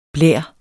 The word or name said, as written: blær